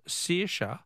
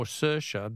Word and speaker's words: Saoirse: Helen from Footscray says, Phil, the Irish name Saoirse is pronounced either Saoirse or Saoirse, depending on which county you come from.